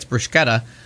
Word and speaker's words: bruschetta: They pronounce every goddamn letter in that sentence, and that's why it's bruschetta. Because there's a fucking C in there and you don't not pronounce anything. – That seems smarter. – And if it's CCH, then it's a K.